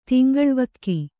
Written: ತಿಂಗಳ್ವಕ್ಕಿ

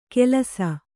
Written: ಕೆಲಸ

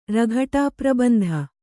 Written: ರಘಟಾಪ್ರಬಂಧ

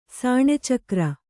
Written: ಸಾಣೆಚಕ್ರ

